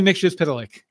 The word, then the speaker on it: Mxyzptlk: So yeah, definitely Mxyzptlk.